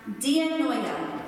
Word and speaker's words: dianoia: This would be pronounced dianoia. Say that. Dianoia. Yeah, dianoia. The Greek for mind.